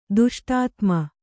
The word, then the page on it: ದುಷ್ಟಾತ್ಮ: Kannada